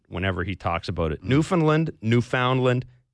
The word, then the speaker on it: Newfoundland: The locals back home, we always the emphasis on land. So it, I always say the easiest way to remember it is understand Newfoundland. – Understand Newfoundland. – It rhymes with understand. Exact same emphasis, same everything.